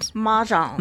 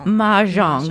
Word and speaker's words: Mahjong: Mahjong. – Mahjong.